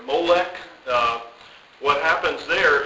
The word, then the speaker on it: Molech: They come along and they call him Molech, which comes, this is how they do this, they took the vowels from another word, Bosheth, which means shame.